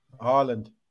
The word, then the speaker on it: Haaland: How do you say his name? – Haaland. – Yeah.